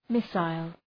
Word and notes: missile: ‘mısəl, ‘mısaıl

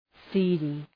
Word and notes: seedy: ‘si:dı

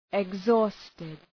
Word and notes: exhausted: ıg’zɔ:stıd

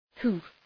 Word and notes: hoof: hʋf

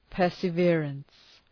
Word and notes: perseverance: ,pɜ:rsə’vırəns

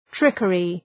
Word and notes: trickery: ‘trıkərı

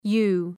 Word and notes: yew: ju:

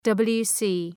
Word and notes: WC: ‘wɒtər,klɒzıt

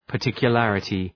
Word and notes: particularity: {pər,tıkjə’lærətı}

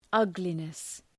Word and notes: ugliness: ‘ʌglınıs

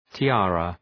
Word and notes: tiara: ti:’ærə

